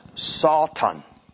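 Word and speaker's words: Satan (שטן): In the Hebrew, it's pronounced Satan. S-A-W-T-A-N. Satan. That's the way they would say it.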